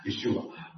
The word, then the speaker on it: Yeshua: For those of you who don't know, that's the Aramaic way of pronouncing the name of Jesus, Yeshua.